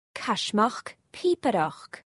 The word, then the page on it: Caismeachd pìobaireachd: Scotland